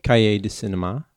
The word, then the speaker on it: Cahiers du Cinéma: Cahiers du Cinéma.